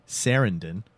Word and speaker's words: Sarandon: Or if you prefer the pronunciation, Susan Sarandon.